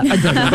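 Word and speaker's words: Adéla: Adela. You're saying it wrong, Kelly. It's Adela.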